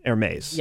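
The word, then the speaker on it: Hermès: No, it's spelled Hermes, but it's pronounced Hermes.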